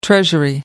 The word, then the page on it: treasury: American English